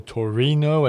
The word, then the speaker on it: Torino: Well, the thing I remember most of all was we went to Turin, or Torino, as the Italians would pronounce it, on a day when we probably shouldn't have gone there.